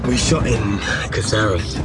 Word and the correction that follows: Cáceres: I should pronounce it, but in Cáceres. That's how it's pronounced.